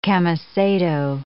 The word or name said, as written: camisado